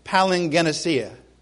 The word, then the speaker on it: Palingenesia: Palingenesia. That's how it's kind of Palin. I'll say Palin. Let me see. Is it an A? Is it a hard A? No, it's not Palin. Palingenesia.